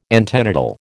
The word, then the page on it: antenatal: American English